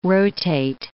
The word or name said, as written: rotate